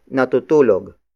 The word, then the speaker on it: natutulog: Aso is spelled as A-S-O. It means dog, the focus of this sentence. The second sentence begins with natutulog. Natutulog. Natutulog. Natutulog is spelled as N-A-T-U-T-U-L-O-G.